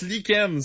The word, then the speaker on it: lichen: Pourquoi on le prononce lichen? C'est quoi le rapport? – Ça devrait être écrit comme L-I-K-E-N-N-E.